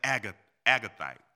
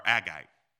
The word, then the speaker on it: Agagite: Now, Haman was an Agagite or Agite. It's one of those. You can pick which pronunciation you want to get.